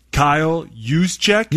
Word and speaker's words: Juszczyk: Kyle Juszczyk.